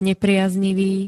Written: nepriaznivý

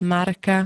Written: Marka

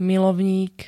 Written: milovník

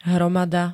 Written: hromada

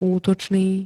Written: útočný

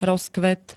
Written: ROZKVET

